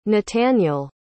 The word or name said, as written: natanael